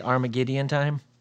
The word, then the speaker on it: Armageddon Time: Armageddon time? – Yep.